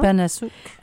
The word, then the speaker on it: Panasuk: On a eu un gros coup de cœur par nos lecteurs du livre de Anne Panasouk. J'espère que je prononce bien son nom. – Anne Panasouk. – P-A-N-A-S-U-K.